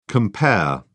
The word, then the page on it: cf.: British English